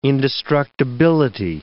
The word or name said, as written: indestructibility